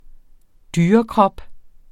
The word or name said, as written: dyrekrop